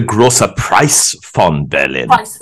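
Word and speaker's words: Großer Preis von Berlin: I've been looking at the Grosse Prix von Berlin in Hopgarten, and that looks like a really interesting race. – Surely the Grosse Preis von Berlin. – Sorry, the correct pronunciation.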